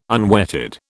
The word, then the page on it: unwetted: British English